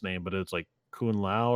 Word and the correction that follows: Kunlun: Kun Lun.